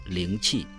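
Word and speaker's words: Линчи (灵气): Но против всего напоминаю, что эти два иероглифа «Рейки» произносятся как «Рейки» только по-японски, а в китайском языке их произношение «Линчи», но они имеют одинаковый смысл.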